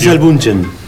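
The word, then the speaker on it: Gisele Bündchen: Giselle Bunchen.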